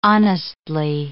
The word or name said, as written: honestly